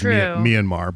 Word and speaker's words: Myanmar: Because I was saying Myanmar for the longest time, and I'm told it was Myanmar. But it's one of those words that... – Well, you get to say it like Michael Flynn. Manamana.